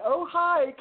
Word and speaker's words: Ojai: sought-after practitioner in her OHA. OHA, is that, did I pronounce that properly? – Ronelle, it's Ojai, California Clinic, and so we're really pleased to share her work with you all.